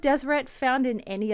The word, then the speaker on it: Deseret: And in the lower kingdom, it talks about Deseret, and it's all consonants. There's no vowels in there. It's D-S-R-T, but it would have been pronounced something along the lines of Deseret.